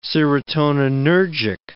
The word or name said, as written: serotoninergic